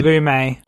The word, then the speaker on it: Lumet: Sydney Lumet. – Yeah. – Lumet. – What? – That's Lumet.